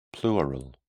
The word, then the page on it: pleural: British English